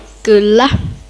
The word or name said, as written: kylla